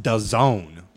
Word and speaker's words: DAZN: And it's it's spelled DAZN, but it's pronounced DAZN. Like, is this is DAZN.